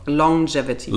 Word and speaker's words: Longevity: Longevity.